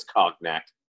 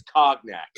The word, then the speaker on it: cognac: Can you please say it correctly? It's cognac. – Yeah, I'm sorry. I'm sorry. – You're Cavassier. A fucking idiot. It's cognac.